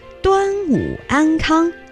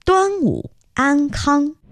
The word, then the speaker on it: 端午安康: Quite right, except that your pronunciation needs to be improved. It should be 端午快乐, Happy Dragon Boat Festival, 端午快乐, or 端午安康, wishing you health during the 端午 Festival, 端午安康.